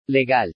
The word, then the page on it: LEGAL: spanish